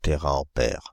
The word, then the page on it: téraampère: [te.ʁa.ɑ̃.pɛʁ]; Paris; France (Île-de-France); /te.ʁa.ɑ̃.pɛʁ/